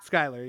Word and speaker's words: Schuyler: Schuyler, yeah.